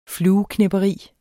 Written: flueknepperi

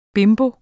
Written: bimbo

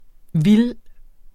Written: vildt

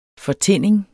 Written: fortinning

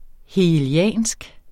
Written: hegeliansk

